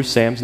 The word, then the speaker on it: Sams: And by the way, yes, it sounds like I have two first names, but Sams is spelled S-A-M-S. Some of you put S-A-M apostrophe S, like it's possessive or something.